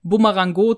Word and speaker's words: Bumerango: Und Willi hat dann die Idee, den Typen Bumerango zu nennen. Mit Betonung auf dem O.